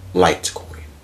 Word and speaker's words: Litecoin: But it's spelled like L-I-T-E. – Litecoin. – Yeah, like light.